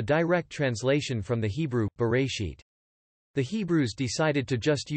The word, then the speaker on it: Bereshit: The Hebrew title for Genesis is Bereshit, pronounced Bereshit. Bereshit simply means, in the beginning.